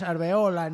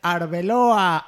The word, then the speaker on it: Arbeloa: Torres atrás, sube Álvaro a Arbeloa, que yo sé pronunciarlo bien, no es Arbeola ni Alberola ni cosa así, es Arbeloa, Arbeloa, hay que vocalizar, Arbeloa, no Arbeola ni cosas así como dicen en Telemadrid y cosas raras.